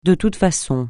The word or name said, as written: de toute façon